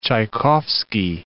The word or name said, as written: Tchaikovsky